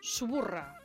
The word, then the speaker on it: Suburra: Subusa. Com ho pronunciem, això? – Suburra. – O Suburra.